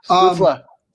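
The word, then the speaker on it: Stützle: I keep saying his name wrong. Stutzla.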